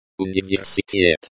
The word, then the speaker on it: Universität: Universität. Universität, university, this is a common word. Pay attention to the stress on the fourth syllable.